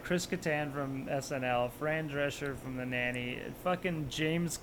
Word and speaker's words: Drescher: Drescher, yeah.